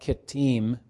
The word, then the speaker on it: Kittim: The sons of Javan were Elisha, Tarshish, Kittim, or I will often say that Kittim because the I-M ending, is that's how it's pronounced in Hebrew, and Dodanim.